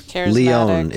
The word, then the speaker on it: Lyonne: Lyonne is the official pronunciation. – Thank you.